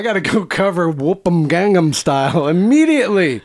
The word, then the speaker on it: Gangnam Style: Kind of like an earlier version of like what maybe we would know like Gangnam Style, right? Like, is that how you say it? – Gangnam Style.